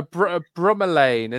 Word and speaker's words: bromeliad: How do you pronounce it? – Bromeliad is the way I say it.